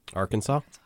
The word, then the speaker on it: Arkansas: I don't know how to pronounce this state though. It's Arkansas. – Arkansas.